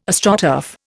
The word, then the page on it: Astroturf: Indian